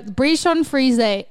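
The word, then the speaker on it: Bichon frisé: So Bichon Frise... pronounced Bichon frise.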